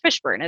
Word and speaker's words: Fishburne: And then my last name is Fishburne. It's spelled exactly like it sounds.